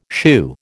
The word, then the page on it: shew: American English